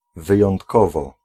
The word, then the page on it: außerordentlich: [ˈaʊ̯sɐʔɔʁdɛntlɪç]; /'aʊ̯sərordɛntlɪç/